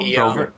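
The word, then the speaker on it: Brevoort: Tom, I'm going to butcher his last name, Brovert. – Oh, Brovert. – Brovert, thank you.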